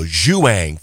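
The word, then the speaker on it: João: And by the way, that's how you pronounce the J-O-A-O, Zhuang Fonseca in straight sets.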